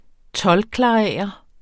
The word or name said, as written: toldklarerer